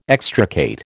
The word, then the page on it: extricate: American English